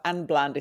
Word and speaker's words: Anne Bland: And it's so funny, you know, every time people introduce me, because I'm Finnish, so I pronounce it Anne Bland.